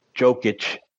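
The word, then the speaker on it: Jokić: Boston showed Philly and their new MVP, which is well deserved because I had picked Jovic, is that how you say it? – Jokic.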